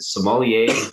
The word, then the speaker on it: sommelier: I saw that you got quite a resume, and from culinary school to, I'm going to butcher it, is it sommelier, like the wine, is that how you pronounce it? Sommelier? Yeah. Perfect.